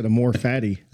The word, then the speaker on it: amor fati: A-M-O-R-F-A-T-I.